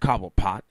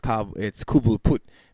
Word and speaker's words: Cobblepot: Not nostalgic, but a nice little, you know, hey, it's not pronounced Cobblepot, it's Kubulput, because it's, you know, fucking, what is it, French, or some shit like that.